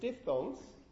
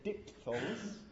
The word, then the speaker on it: diphthongs: Because remember that last time when I introduced diphthongs, in fact quite a few Brits say diphthongs, I think the Americans as well, the majority say diphthongs, apparently according to the pronunciation dictionary, but both diphthongs and diphthongs is fine.